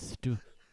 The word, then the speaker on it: Stoughton: Guys, how do you pronounce it? – it's actually pronounced Stoughton.